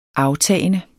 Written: aftagende

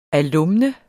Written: alumne

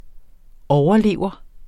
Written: overlever

